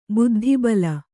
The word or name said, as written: ಬುದ್ಧಿಬಲ